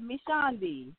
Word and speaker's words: Mishondy: I think you did, Mishondy.